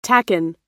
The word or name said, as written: tacan